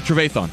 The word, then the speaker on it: Trevathan: Was, how do you pronounce his name? Trevathan? – Yeah, Trevathan.